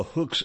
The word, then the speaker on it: Huk: As we will look at, although the Hooks eventually, that's spelled H-U-K, although they eventually did become Marxist guerrillas, mostly by default after having been rejected by the Americans.